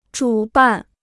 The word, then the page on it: 主办: zhǔ bàn